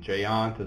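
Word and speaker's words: GÉANT: Is that how you pronounce that? G-E-A-N-T? – GEANT. – Oh, okay. – Yeah. It's Europe, after all.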